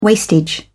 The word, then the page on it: wastage: British English